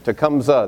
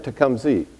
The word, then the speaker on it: Tecumseh: Did I pronounce it right? – Tecumseh. – That's the way I would say it. But I heard Tecumseh. That's not right? Is it both ways? – Depends who you talk to.